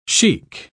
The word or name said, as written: chic